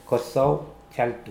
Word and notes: koasoajaltu: Mokilese

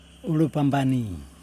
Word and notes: ulupambani: Yokoim

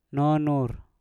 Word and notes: nonnur: Mpur

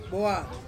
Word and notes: boa: Ansus

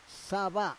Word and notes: sava: Roon

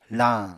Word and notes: lañ: apatani

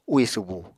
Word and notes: úì subu: Apatani

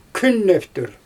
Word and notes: künnEftür: Xyzyl

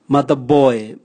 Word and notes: mataboe: Raga

